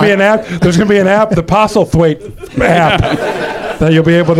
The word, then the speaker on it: Postlethwaite: Lucas Haas, Michael Caine, and Pete Postlethwaite, the great late Pete Postlethwaite, that is pronounced Postlethwaite. On the show one time, I said Postlethwaite, and everybody made fun of me saying it was Postlethwaite, and I couldn't prove it on the spot because...